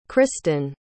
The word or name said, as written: cristyn